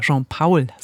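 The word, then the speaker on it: Jean Paul: Und Jean-Paul meint, oder Jean-Paul, so spricht man ihn aus, meint, finde ich auch sehr schön, Bücher lesen heißt wandern gehen in ferne Welten aus den Stuben über die Sterne.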